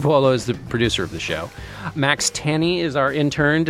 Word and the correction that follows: Tawny: Did I pronounce that correctly, Max? – It's Tawny. – Tawny. Yeah. Damn. I'm sorry, Max. Max Tawny.